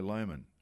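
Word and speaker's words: Lohmann: And Jared Lyon, sadly, he's missed his spot with Kai Lohman. That's how you say it, didn't Lomas.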